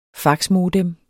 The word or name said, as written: faxmodem